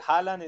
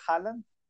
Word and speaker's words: Haaland: Listo, no, pues Haaland es H-A y Land como de tierra, Haaland.